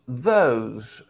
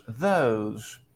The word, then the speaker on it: those: Ahí tenemos la O, espacio E, se pronunciaría O, ¿no? Igual que la letra O. O space E sounds like O. O's, those.